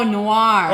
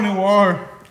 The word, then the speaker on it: Noir: No, no Mar. – No, Noir. – Oh, Noir. I think así. That's how you say it.